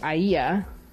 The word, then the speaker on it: Aiaia: And it, you know, the spellings are various, but it's basically A-I-A-I-A. Sometimes it is A-E-A-E-A. And so I've been pronouncing it as Aia. I don't know if that's how the ancient Greeks would have pronounced it, but that's my best guess.